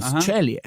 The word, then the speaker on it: Ceglie: Da ist er aufgewachsen, der 26-jährige Chase Chellier. Man schreibt das C-E-G-L-I-E. – Hast du einen Italiener? – Anscheinend schreibt man es italienisch aus. Cellie. – Cellie. – Ja, Cellie. Chase Cellie.